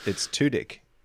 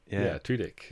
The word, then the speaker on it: Tudyk: I think you taught me how to pronounce his name. – Well, it's Tudyk. – Tudyk.